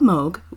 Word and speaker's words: Moog: That's right, I'm talking about Bob Moog, whose name looks like it should be pronounced Moog, but is not. Much like Brett Favre, it's not pronounced the way it looks. Bob Moog was born in Flushing, in Queens, in New York City on May 23, 1934.